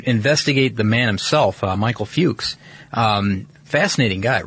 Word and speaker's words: Fux: Michael Fuchs. – Yeah, Fuchs. And it's spelled F-U-X. Fuchs is how you pronounce that. – Pronounce it Fuchs.